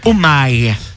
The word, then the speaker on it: OMI: Et puis il y a même le hashtag Omi sur Skyrock, O-M-I. – Et a priori, ça se prononce Omai. – Omai. – Ouais, j'ai entendu ça sur des radios américaines. – Réchi, Tim Romano qui dit ça. On prononce Omai, pas Omi.